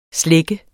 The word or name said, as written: slække